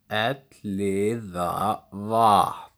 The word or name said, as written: Elliðavatn